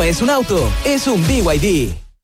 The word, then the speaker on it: BYD: ¿No es BYD? – Nada que ver, se escribe BYD, pero se dice BYD, la marca número uno de electromovilidad en Chile y el mundo.